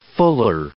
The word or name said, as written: fuller